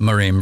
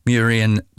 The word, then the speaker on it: Muirean: But anyway, if you look up Miriam Bradley, which is spelled M-U-I-R-E-A-N, Miriam Bradley. That's the Irish name. I think I've got the pronunciation right, but if not, I've no doubt I'll be told before very long.